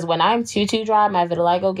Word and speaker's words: vitiligo: I have a question about your vitiligo. – Yes. – Am I saying it right? Vitiligo? – Yeah, you are.